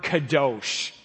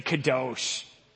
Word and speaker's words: kadosh: The word used for holy is the word kadosh. Say kadosh. Well done. So this word kadosh is a word that means holy. And to translate that, essentially what kadosh means is to be set apart.